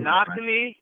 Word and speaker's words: Nakni: Nakni Anapuli.